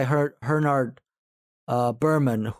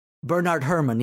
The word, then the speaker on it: Bernard Herrmann: It's very Hitchcockian in style, very similar to Vertigo, and it even has a beautiful soundtrack by Bernard Herrmann, who also... Bernard Herrmann, yeah, sorry. – No, I mispronounced it as well.